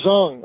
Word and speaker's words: Dzong: the D-Z-O-N-G. Would you tell us how to correctly pronounce that, and what is a D-Z-O-N-G? – Ah, Zong.